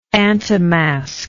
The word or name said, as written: antimasker